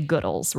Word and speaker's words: Goodles: It says, you can say it however it makes you happy, but we choose to emphasize the good in Goodles. We say Goodles rhymes with wood.